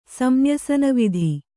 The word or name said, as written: ಸಂನ್ಯಸನವಿಧಿ